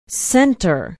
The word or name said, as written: center